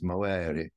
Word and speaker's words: movere: It's derived from the Latin word movere or movere. I think they, the Romans would have pronounced it like the Italians, movere.